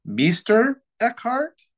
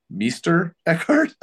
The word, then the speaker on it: Meister Eckhart: and it says Meister Eckhart.